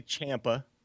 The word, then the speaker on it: Ciampa: You pronounce that correctly, Ciampa.